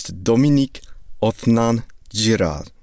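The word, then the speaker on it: Dominique Othenin-Girard: Uh, my friend who was thinking of being part of this podcast before, he gave me an audio recording of how to pronounce the goddamn director's name. So it's Dominique Othenin Girard. There you go.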